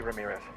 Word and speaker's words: Ramirez: Jamie Ramirez. – Jamie Ramirez, can you spell that for me? – R-A-M-I-R-E-Z.